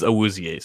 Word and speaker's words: Awuzie: The pronunciation is Awuzie. So okay. Kudos to you for actually getting that.